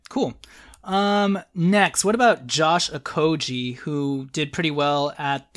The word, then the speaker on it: Okogie: Well, first of all, you pronounced Okoji right. We've been saying Okoji for 5 months.